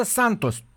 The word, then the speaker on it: Santos: Aici am, se pronunță Santos. O viață întreagă am crezut că se pronunță... Brazilienii nu fac și eu ca portughezii. Adică este limba portughezul-braziliană, da? No. Cum e limba engleză-americană, da? Uh-huh. Atenție, Santos.